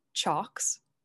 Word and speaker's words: chocks: C-H-O-C-K-S, like chalk. – Oh, okay. – No L in there.